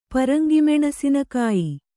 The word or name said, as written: ಪರಂಗಿಮೆಣಸಿನಕಾಯಿ